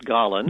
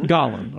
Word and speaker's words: Galen: Well, first of all, is it pronounced Galen? – I pronounce it Galen. – Galen, okay. – So that's the way the Germans do, more or less. – Sure, yeah, that's fine. – It's von Galen, yeah.